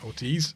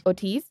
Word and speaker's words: Ortiz: This is Speedy Ortiz. – Yeah, Ortiz. – Ortiz. I always say that wrong.